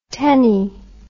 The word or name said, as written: tenny